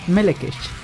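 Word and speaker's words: Melechesh: La CH se pronuncia como CH escocesa, como la palabra Loch o la G holandesa. KG. Tiene como. – KH. – Claro, tiene pronunciaciones bien raras. – Sí. – Bueno, ahí es como común. – Bueno, otra pronunciación aceptada y común es Melekech.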